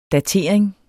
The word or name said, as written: DATERINGEN